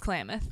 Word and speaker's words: Klamath: Yeah, it's Klamath. – Then, okay, cool. – Because that's how it's spelled, for sure.